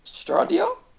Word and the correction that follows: Stadia: How do you pronounce it? – Stadia. – Oh, Stadia. – Like stadium. It's the Greek name for stadium.